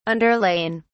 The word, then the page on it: underlain: British English